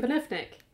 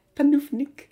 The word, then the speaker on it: Panufnik: How do you say her surname? Panufnik. Panufnik. Yeah. Panufnik.